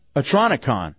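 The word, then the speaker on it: Otronicon: Also, too, we got the pair of tickets to the Orlando Science Center for the Oconotron. Am I saying that right? – Yeah. – I don't think I am. Atronicon. That's how you say it. Atronicon.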